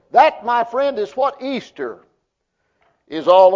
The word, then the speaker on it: Eastre: but the word Easter is believed to come from the word E-A-S-T-R-E, pronounced still the same way, Easter, which is an Anglo-Saxon name for a pre-Germanic goddess of spring and fertility.